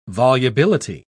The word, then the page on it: volubility: British English